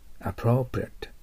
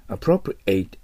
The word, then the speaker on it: appropriate: appropriate、 appropriate、これがふさわしい、適切なという形容詞です。ちょっと難しいですよね。動詞だったら appropriate と発音すると思います。 appropriate A to B。